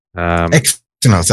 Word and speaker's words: Exynos: So the S24 is a 6.2-inch screen, and it has the Zynos chip. – Exynos, I think, isn't it? – All right. Yeah. Exynos. Yeah. Whatever it's pronounced anyway.